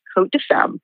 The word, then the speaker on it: Côtes de Femme: And the S is silent, and just like in France, you know, places like Côte de Bone are spelled with the S, but it's silent, so it's pronounced Côtes de Femme.